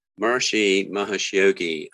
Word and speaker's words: Maharishi Mahesh Yogi: Maharishi Mahesh Yogi, um, you know, the Beatles uh, learned transcendental meditation from Maharishi.